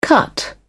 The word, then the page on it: cut up: British English